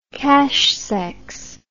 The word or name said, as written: cache-sexe